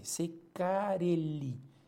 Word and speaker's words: Ceccarelli: Yo estoy pronunciando Che como se pronuncia en italiano, no es Ceccarelli, ¿no? Y Carelli es con doble C, Ceccarelli. O Ceccarelli, ¿no? Con doble L.